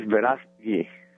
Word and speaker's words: Verástegui: Let me see if I pronounce it right, Eduardo. Verástegui? – It's Verástegui. – Verástegui. – Verástegui. Very good.